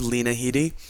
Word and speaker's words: Headey: But not only was Lena Headey's performance as Sarah Connor, and I mispronounced her name earlier, it's, I believe, Lena Headey.